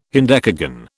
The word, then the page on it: hendecagon: British English